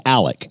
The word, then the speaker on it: Alex: And then at the actual funeral itself, according to a couple of different podcasts that I went through, Alex Murdoch, I'm sorry, I keep saying Alex because it's an X, but it's... – It's spelled A-L-E-X. – But it's pronounced Alec. Everybody, Alec his family members that I've seen and heard, they say Alec.